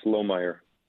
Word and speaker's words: Lohmeier: Yes, it's Lohmeier.